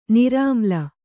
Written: ನಿರ್ಮಲೆ

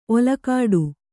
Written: ಓಲೆಗೊಡೆ

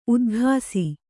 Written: ಉದಾಗಸ

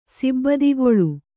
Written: ಸಿಬ್ಬಿದಿಗೊಳ್

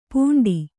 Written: ಪುಂಡಿ

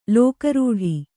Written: ಲೋಕಾರೂಢಿ

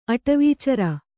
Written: ಅಟವೀಚರ

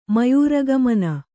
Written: ಮಯೂರಗಮನ

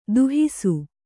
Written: ದಾಹಿಸು

